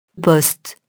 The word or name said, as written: post-